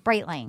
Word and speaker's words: Breitling: Breitling? – Breitling.